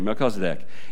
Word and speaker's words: Melchizedek: Now you know how to say his name, Melchizedek.